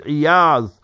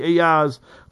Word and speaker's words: Iyaz: The name is Iyaz. People mispronounce it, misspell it in English. Qadi Iyaz.